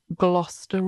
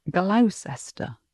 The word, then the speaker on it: Gloucester: spelt L-E-I-C-E-S-T-E-R. You might think it was Leicester Square or Leicester Square. Similarly, Gloucester Road, spelt G-L-O-U-C-E-S-T-E-R. So it looks like Gloucester, but that's pronounced Gloucester.